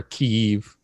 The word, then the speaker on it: Kyiv: Kyiv, the Russian pronunciation, or Kyiv in Ukrainian.